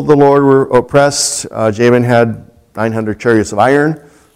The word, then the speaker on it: Jabin: God sold them into the hand of Jabin, king of Canaan, Yabin, and pronounced in Hebrew, who reigned up in Hazor or Hatzor.